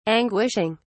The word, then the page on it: anguishing: British English